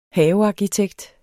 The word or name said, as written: havearkitekt